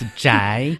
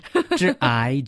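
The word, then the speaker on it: Zhai (宅): In Chinese, It's called Zhai. – Yes. – A little hard to pronounce in Chinese, but translating into English... – Okay, pronounce after me. It's Zhai. Zhai, Zhai. Well, yeah, in Chinese, it literally means the house.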